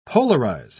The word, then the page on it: polarise: British English